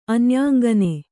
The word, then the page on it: ಅನ್ಯಾಂಗನೆ: kannada